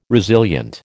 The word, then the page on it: resilient: American English